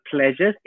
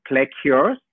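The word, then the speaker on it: Pleacures: But our word pleasures is spelled with a P L E A C U R E S. So it's like plecures. uh, but it's we, we just call it Simple Pleasures.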